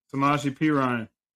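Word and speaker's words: Samaje Perine: Samaji Perine.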